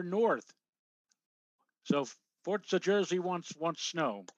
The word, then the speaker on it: Forza: Roll your R. It's Forza.